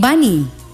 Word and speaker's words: Baní: Nació en Baní, con el acento en la I, República Dominicana.